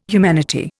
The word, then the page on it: humanity: Indian